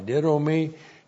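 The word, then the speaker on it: didomi (δίδωμι): D-I-D, long O-M-I, didomi. Didomi is the verb to give.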